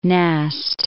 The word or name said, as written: Nast